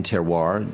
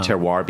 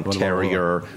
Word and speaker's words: Terroir: Terroir became Terrier, right. – Terror, blah, blah, blah.